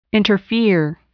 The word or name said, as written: interfere